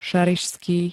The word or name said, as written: ŠARIŠSKÝ